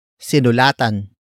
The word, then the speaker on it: sinulatan: Word for word it's sinulatan, ko, si, mayor.